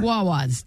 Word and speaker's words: Wawa: A couple of women got locked out of their car outside a, is it pronounced Wawa store? – Wawa's, yes.